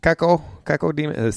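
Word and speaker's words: Cacodemon: Caco Demon. C-A-C-O. – Caco or Coco? – I said Coco because I'm hungry. – You say Coco, I say Caco. – Caco. It's C-A-C-O Demon. – C-O-C-O? – Keko.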